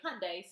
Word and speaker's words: Hyundai: It was a Honda Hyundai. – Yes. – So we learned how to say it Sunday, Monday, Hyundai.